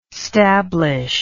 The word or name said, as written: stablish